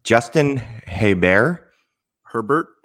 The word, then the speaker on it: Herbert: Justin Herbert. – Mm-hmm. – Rhymes with Sherbert.